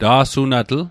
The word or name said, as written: ta'sunatl